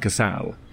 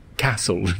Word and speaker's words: Cassel: well, I would say that Vincent Cassel, that's how you say his name. Vincent Castle just sounds like, you're getting it wrong.